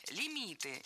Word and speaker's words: лимит: А подвох в том, что слово это латинское, а пришло в русский язык оно из французского и сохранило ударение французское на последнем слоге – лимит.